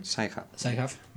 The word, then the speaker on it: Sci-Hub: El otro día lo hablábamos, de hecho tengo por aquí apuntado el nombre de Alexandra Albakian, que permitió, gracias a Sci-Hub, creo que lo he dicho bien. – Sci-Hub, lo siento, mi pronunciación ha sido buena.